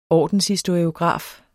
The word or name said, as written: ordenshistoriograf